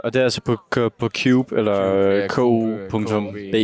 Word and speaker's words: Kube: De spiller på det, der hedder Cube, tror jeg, man udtaler K-U-B-E på Cube.